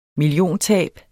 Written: milliontab

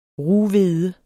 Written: rughvede

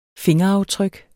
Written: fingeraftryk

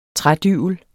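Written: trædybel

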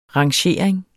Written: rangering

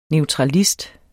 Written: neutralist

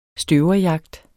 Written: støverjagt

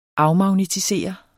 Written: afmagnetisere

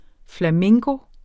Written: flamenco

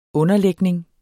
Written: underlægning

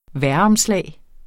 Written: vejromslag

